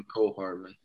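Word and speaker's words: Mecole Hardman: And McCole Hardman, is that how you pronounce his name? – Yeah, McCole Hardman.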